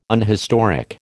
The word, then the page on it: unhistoric: American English